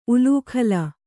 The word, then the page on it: ಉಲೂಖಲ: ulūkhala